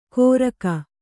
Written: ಗೊರಗ

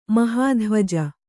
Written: ಮಹಾಧ್ವಜ

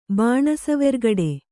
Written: ಭಾಣಸುವೆರ್ಗಡೆ

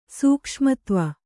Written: ಸೂಕ್ಷ್ಮತ್ವ

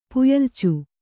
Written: ಪುಯ್ಯಲ್ಚು

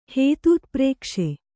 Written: ಹೇತೂತ್ಪ್ರೇಕ್ಷೆ

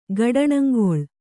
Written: ಗಡಣಂಗೊಳ್

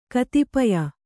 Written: ಕತಿಪಯ

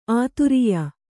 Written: ಆದೊರೆಯ

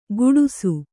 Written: ಗುಡುಸು

